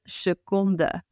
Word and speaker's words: seconde: Second, again, very Dutchifiable, except we do want to pronounce the E as it is in our word. De seconde.